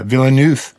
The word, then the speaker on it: Villeneuve: It's directed by a guy named Denis Villeneuve, and I'm probably butchering his name, but he's a French-Canadian guy. – Villeneuve? – Yeah, Villeneuve, yes.